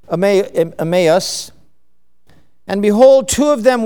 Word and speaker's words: Emmaus: Now you look that up and it's pronounced Emmaus.